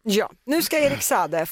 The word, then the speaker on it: Saade: Saade.